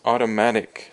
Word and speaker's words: automatic: And then the next word is automatic. So if something is automatic, it is 自动的. So the emphasis is on the ma, the auto ma. That's the third syllable.